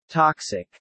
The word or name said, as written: toxic